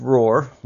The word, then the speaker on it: R04R: Well, let's talk about your company, Roar, and it's pronounced Roar, like a lion, tiger, or a bear.